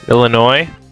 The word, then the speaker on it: Illinois: The S is silent. It's a silent S. – Illinois.